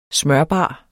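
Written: SMØRBAR

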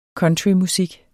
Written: countrymusik

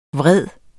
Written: vred

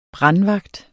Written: brandvagt

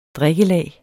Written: drikkelag

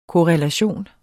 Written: korrelation

